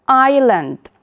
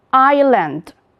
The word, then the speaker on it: island: Now, boys and girls, pay attention to this word, island. Island. Letter S has no pronunciation in this word.